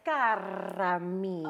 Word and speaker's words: Kira Mia: And if you could roll your R's, it's Kiara Mia.